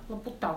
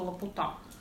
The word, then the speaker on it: Лапута: К антиутопиям относится частично одним кусочком Свифт, потому что там есть летающий остров, который я все время путаю ударение. – Лапута. – Вот мне тоже кажется, что Лапута.